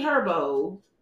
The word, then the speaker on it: G Herbo: G Herbo.